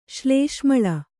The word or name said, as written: ಶ್ಲೇಷ್ಮಳ